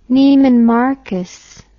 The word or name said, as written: Neiman Marcus